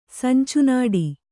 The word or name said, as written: ಸಂಚುನೋಟ